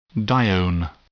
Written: dione

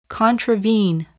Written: contravene

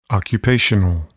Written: occupational

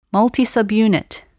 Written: multisubunit

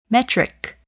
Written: metric

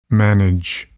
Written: manage

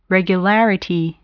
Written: regularity

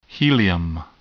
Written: helium

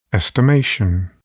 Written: estimation

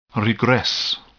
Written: regress

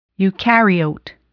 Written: eucaryote

